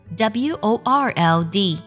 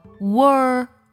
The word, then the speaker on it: world: 比如，我们来看几个单词：小鸟 b i r d 读作 bird bird；学期 t e r m 读作 ter ter term；世界这个单词 w o r l d 读作 world world world world。